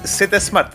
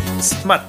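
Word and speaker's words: Zmart: Z eh, es que no dice, no es Z Smart, es Smart. Con Yo sí, yo Z Mark.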